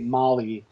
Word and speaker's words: Mali: Yeah, well, she goes by Molly, which is spelled M-A-L-I, where she basically took her first and middle name and, like, smashed them together.